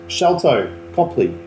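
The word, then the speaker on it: Sharlto Copley: It's Sholto Copley, who is...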